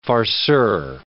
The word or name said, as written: farceur